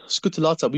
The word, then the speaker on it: scutellata: You've got this bee there called the Apis mellifera scutellata. Is that how I pronounce it? – Yes, scutellata.